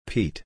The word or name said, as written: Pete